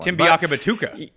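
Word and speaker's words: Biakabutuka: So I think that the only one I could always say back in the Bay was Tim Biakabatua. I don't know why I could. You just could always say that one. – Tim Biakabatuka. Batuka. – Yeah. I even said it wrong then.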